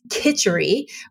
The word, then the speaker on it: khichdi: I pronounced it wrong. I said khichari. It's khichdi reset, what that is.